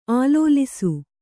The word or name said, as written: ಆಲೋಲಿಸು